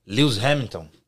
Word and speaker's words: Lewis Hamilton: A pronúncia em inglês, Lewis Hamilton. Que eu gosto muito de Fórmula 1, né? E é assim que fala.